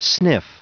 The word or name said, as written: sniff